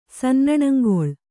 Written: ಸನ್ನಣಂಗೊಳ್

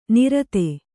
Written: ನೈಋತ

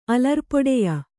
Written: ಅಲರ್ಪೊಡೆಯ

